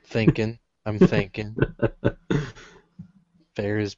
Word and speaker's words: Ferris: I think there's a slight pronunciation difference between Ferris and Ferrus. – Ferris. Ferris. – Ferrous. – Ferris. – As in having similar or the qualities of iron.